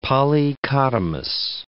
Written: polychotomous

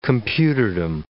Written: computerdom

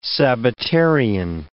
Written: Sabbatarian

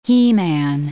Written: he-man

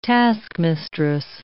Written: taskmistress

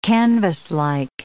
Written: canvaslike

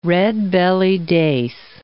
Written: redbelly dace

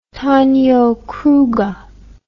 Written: Tonio Kroger

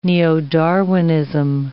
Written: neo-Darwinism

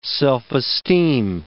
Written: self-esteem

